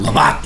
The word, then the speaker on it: Lavazza: Lavazza? – Yeah. Okay. Lavazza, like L-A-V, A-T-Z-A, Lavazza.